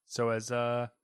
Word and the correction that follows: Nagoshi: however you say his last name, probably butchering it, sorry. – Probably Nagoshi. That's very American of me.